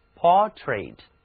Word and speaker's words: portrait: portrait portrait 是专门指人物肖像或者景物画像，这个词它的重音在第一个音节上，字母 OR 读作 O，而 AI 读作短的 E。 portrait That is a portrait of Audrey.